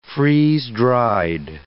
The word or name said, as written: freeze-dried